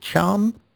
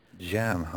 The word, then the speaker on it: Can: is it Chan? Is that how we pronounce him? – Jan, I believe. – Jan, okay. – But there was a debate about this. – Bridge, in Bridge. – Yeah, even our Turkish speakers didn't agree on how you pronounce it. But right, okay. – It's not Can, anyway.